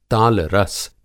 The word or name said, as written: तालरस